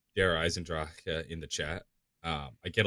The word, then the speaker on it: Der Eisendrache: But to those who want to hear it, Der Eisendrache. That's how I say it.